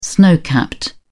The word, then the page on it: snow capped: British English